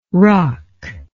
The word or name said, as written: rok